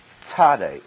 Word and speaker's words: Tsade: First of all, for pronunciation, notice in your Bible text, you've got T-S-A-D-E, and that is Tsade, Tsade, sort of the T-S sound.